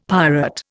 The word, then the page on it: pirate: Indian